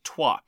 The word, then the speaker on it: Troyes: Number 18 is Trois, which is spelt Troyes and is pronounced by many people. This is not me casting shade, just a description as Trois.